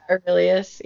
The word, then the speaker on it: Aurelius: And I liked Aurelius. Am I saying that right? – Yeah, Aurelius. Yeah.